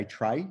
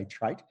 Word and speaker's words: trait: Psychiatrists tend to say tray. Psychologists tend to say trait. Anyway, is that a psychiatry lingo? Is that like urban dictionary? – Yeah. I think psychiatrists just think they're a bit fancy and pronounce it in a bit of a French manner, your personality trait. Whereas okay. Psychologists are more down to earth and they say trait. I think trait makes more sense, but anyway.